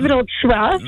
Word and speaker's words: Wrocław: Oui, Wrocław.